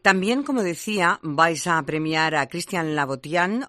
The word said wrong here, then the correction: Louboutin: o Laboutian. ¿Cómo se pronuncia, hijo? – En francés, por lo visto, yo que no soy un gran hablador de francés, pero la OU se pronuncia como una U. – Sí, Laboutian. – Louboutin. – Ah, Louboutin, Louboutin, eso.